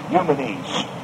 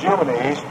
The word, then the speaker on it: Eumenes: Eumenes, whose name was Eumenes, he wanted to have the greatest library in the world, but he didn't have it.